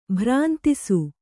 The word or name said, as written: ಭ್ರಾಂತಿಸು